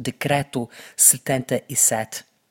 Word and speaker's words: Decreto 77: They are called Decreto 77. I, I asked them how to say their name, and they told me, yes, you can say it in Portuguese because the name of the band is Portuguese, though they, they sing in English.